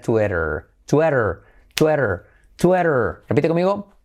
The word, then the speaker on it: Twitter: Muy bien, número 4 en esta lista, que sería lo que decimos en español Twitter, Twitter, pero en inglés fijaos cómo se dice Twitter. Tenemos la I como E, Twitter, la T como R suave y luego la letra clave R, Twitter.